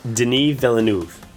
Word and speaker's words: Villeneuve: Also, Sarah Gaydon, who is the Canadian actress, she did the film Enemy, the Denis Villeneuve, I can never pronounce his name. – Denis Villeneuve. – Okay, well, I'm going to have to learn to do that by the time Arrival opens this fall.